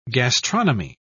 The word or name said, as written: gastronomy